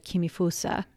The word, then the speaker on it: Kimifusa: By the way, Kōbo is actually the Chinese pronunciation of the kanji that make up his Japanese name. In Japanese, his name is read Kimifusa.